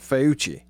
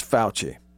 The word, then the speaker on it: Fauci: Now, this is a USA Today a reporter, but they're getting quotes, direct quotes from Dr. Fauci, who I mispronounced his name one day on the show, and deservedly so. Y'all laughed at me because I said Fauci. I really did. It's Fauci.